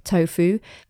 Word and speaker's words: Tofoo: It's spelled T O F O O, tofu,